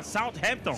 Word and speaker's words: Southampton: South, você tem que botar o ar. Southampton.